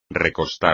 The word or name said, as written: recostar